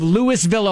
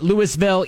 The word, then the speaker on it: Louisville: And so we got it right from the mayor of Louisville, Ohio, that they pronounce it Louisville, even though it's spelled exactly like Louisville, Kentucky.